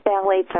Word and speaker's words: phthalates: phthalates, and I'll spell it because if people want to look for it, it's counterintuitive. It's P-H-T-H-A-L-A-T-E-S, phthalates.